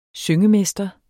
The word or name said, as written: syngemester